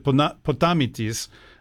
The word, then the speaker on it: Potamitis: in Greek, I would pronounce it Potamitis, but you might see it as Potamitis Publishing.